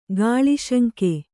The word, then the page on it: ಕಲಿಸಿಂಗ: kalisiŋga